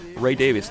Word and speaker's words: Davies: Ray Davies, that's the way he prefers to have his name pronounced.